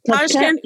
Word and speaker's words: Tashkent: In 2011, she took her experience to Tashkent. Am I saying that right? – Yeah. – Tashkent.